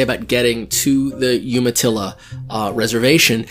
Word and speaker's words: Umatilla: the Umatilla people. Is that, am I pronouncing that correctly? – Yep. – And maybe... – Cayuse Umatilla Walla Walla.